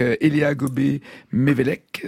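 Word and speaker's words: Éléa Gobbé-Mévellec: formidable film que vous avez co-signé avec Elia Gobé-Mévelec, si je prononce bien. – Oui. Vous avez très bien prononcé.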